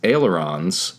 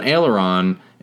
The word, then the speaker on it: aileron: But it's a strange sounding word. – Aileron. – Aileron, but it sounds like the right way to pronounce it, I guess.